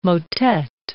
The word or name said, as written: motet